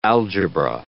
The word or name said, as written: algebra